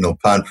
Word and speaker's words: ulpan: An ulpan, pronounced in Hebrew ulpan, actually comes from Aramaic.